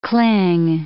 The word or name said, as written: cling